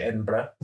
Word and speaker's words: Edinburgh: It's spelled like Edinburgh, like G-H at the end, but they say Edinburgh over there.